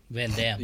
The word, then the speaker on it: Jean-Claude Van Damme: Number three, Mike Patton, and a name I'm going to mispronounce: Jean-Claude Vanier. – Van Damme. – Yeah, Van Damme. – I'm sorry, Jean-Claude Van Damme.